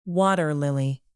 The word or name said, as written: waterlily